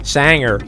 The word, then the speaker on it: Sanger: Is that how you pronounce it? Sanger? – Sanger. – If he's a country singer. – If you want to use the West Coast American, it's Sanger.